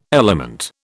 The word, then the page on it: element: British English